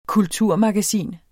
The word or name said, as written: kulturmagasin